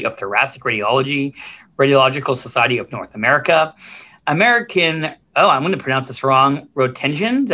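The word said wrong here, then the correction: Roentgen Ray: Did I say that right? – Rankin-Ray. – Oh my God. Everyone's all embarrassed. I screwed that one up.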